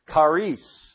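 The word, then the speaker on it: charis (χάρις): The word grace here in Greek is charis. Charis.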